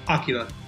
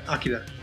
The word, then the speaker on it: Akira: Well, it's because it's correctly pronounced Akira. – Right. – Akira.